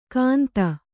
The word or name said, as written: ಕಾಂತ